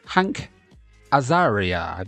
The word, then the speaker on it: Hank Azaria: Of course, the film starred Richard Gere, Julia Roberts, Ralph Bellamy, also Jason Alexander as well, and features, by the way, Hank Azaria, that's how you pronounce it, you know, the guy who's in The Simpsons and Friends and all those different things like that.